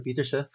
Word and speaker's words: Peterse: So I'm Jasper Peterse, pronounced in Dutch.